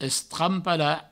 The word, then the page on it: estrampalar: Provençal; estrampala